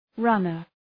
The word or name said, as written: runner